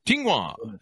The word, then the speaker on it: Tsinghua: Tsinghua.